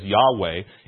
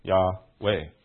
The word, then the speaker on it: Yahweh: You say that as a V, then it can't be Yahweh. I'm just saying Yahweh, right? It's not W, it's V, right? So you're not even saying it right, even it is Yahweh.